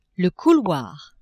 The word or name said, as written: couloir